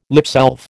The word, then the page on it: lipsalve: American English